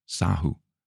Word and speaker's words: Sahu: So I don't know if she's just Catherine or Cathy, but C-A-T-H-Y-S-A-H-U, Sahu. How else would you pronounce that? She said, oh, you've got it right.